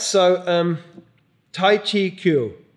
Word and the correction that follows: Guo: Yeah, it's actually Guo, I think. Usually Guo. It's pronounced with a G, but if it's Taiwanese, I'll spell it with a K, but it's pronounced with a G.